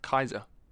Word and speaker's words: Caesar: Apparently his name is supposed to be pronounced Kaiser. – Kaiser? – Yeah, Julius Kaiser, apparently, because they don't have like a C like we have it. – Oh, really? – So Kaiser, the C is supposed to be pronounced as a K.